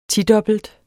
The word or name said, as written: tidobbelt